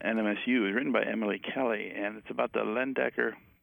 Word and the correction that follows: Leyendecker: if I'm saying it right. – I think it's Leyendecker.